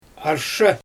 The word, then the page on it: arshE: Xyzyl